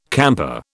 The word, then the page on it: camper: British English